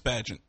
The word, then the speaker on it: Bagent: And I can't imagine you would – you know, it's that Bagenet guy. Is that how you say his name? – I think it's just Bagent. B-A-G-E-N-T. Okay. Tyson Bagent.